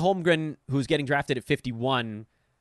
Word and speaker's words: Holmgren: Holmgren, the M is in the middle. The N is at the end.